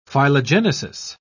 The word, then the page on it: phylogenesis: British English